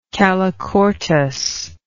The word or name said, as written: calochortus